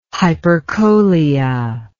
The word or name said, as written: hypercholia